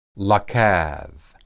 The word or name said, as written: lakad